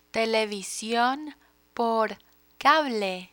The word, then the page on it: television por cable: spanish